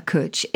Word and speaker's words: Cwtch: I mean, for example, one of the words you've got, and I don't know how to pronounce this, is C-W-T-C-H, which I assume is a Welsh word. – It is a Welsh word. Cwtch, this one is.